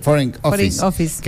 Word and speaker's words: Foreign Office: Sí, Foreign Office.